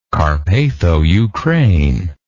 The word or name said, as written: Carpatho-Ukraine